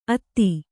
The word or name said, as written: ಅತ್ತಿ